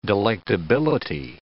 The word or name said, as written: delectability